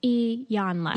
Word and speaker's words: Iyanla: I know I was, because my I call her Yolanda, and that is not her name. There's not even, it doesn't begin with the Y. – Yeah. – And I'm like, Yolanda. – Rob, who was on the biracial episode, loves her. And he responded in my Instagram story and said, be better. Pronunciation.